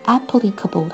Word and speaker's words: applicable: 这个地方要提醒大家，就是这个词的发音，因为 apply 这个词的重音在后，而 applicable 它的这个形容词，它的美式英语发音重音却在前。